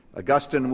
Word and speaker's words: Augustine: Monica was the mother of Augustine, sometimes pronounced Augustine. She prayed for him. She was married to a pagan. Augustine was another mama's boy who grew up without a dad in the home that he could have a relationship with, spiritually speaking.